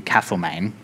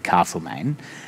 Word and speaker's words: Castlemaine: And like I did an event for this book in Castlemaine, which is how you have to pronounce it. I would have said Castlemaine.